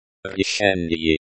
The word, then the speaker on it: решение: Решение. Решение. Means decision. A very useful word. The accent is on the second syllable. Pronounced решение, almost like the English word decision.